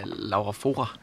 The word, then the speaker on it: Laura Fora: der hedder Laura Fona. Er det rigtigt udtalt? Næsten. Ja, Laura Fora.